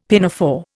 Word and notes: pinafore: Indian

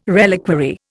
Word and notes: reliquary: Indian

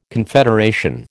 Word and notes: confederation: American English

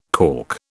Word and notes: calk: British English

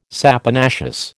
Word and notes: saponaceous: American English

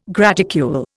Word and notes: graticule: Indian

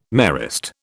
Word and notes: Marist: British English

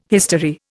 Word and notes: history: Indian

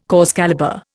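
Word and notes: cause célèbre: Indian

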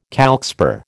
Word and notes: calcspar: American English

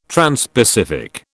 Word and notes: transpacific: British English

